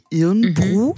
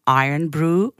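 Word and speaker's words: Irn-Bru: Es schreibt sich Irnbru, mhm. – weswegen wir ja auch die ganze Zeit von Irnbru gesprochen haben. Es wird mhm. aber ausgesprochen Iron Brew, was so viel heißt wie Eisengebräu.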